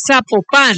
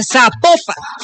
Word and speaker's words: Zapopan: Oiga, nos escriben los oyentes y que no se pronuncia Zapopan, sino que se pronuncia Zapopan. – Ah, Zapopan.